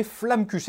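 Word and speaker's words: Flammekueche: La deuxième partie, on l'a nommée Flamme-Cuche. Est-ce qu'on prononce comme il faut? – Ouais, c'est en français, ouais.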